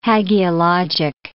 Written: hagiologic

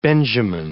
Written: Benjamin

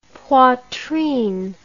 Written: poitrine

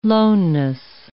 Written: loneness

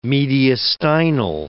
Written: mediastinal

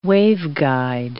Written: waveguide

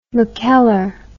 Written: mackellar

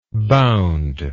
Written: boundable

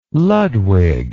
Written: Ludwig

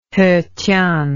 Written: hetian

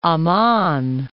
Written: Amman

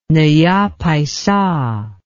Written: naya paisa

